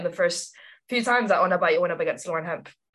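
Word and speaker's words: Ona Batlle: I think everyone listening to this podcast will be well aware of who Ona Batje is, or as the English say, Ona Badger.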